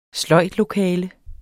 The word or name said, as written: sløjdlokale